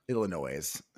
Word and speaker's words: Illinois: Illinois. – Illinois. – Yeah, the S is silent. – My bad. – Although apparently people in Aurora, Illinois say it with the S, I heard.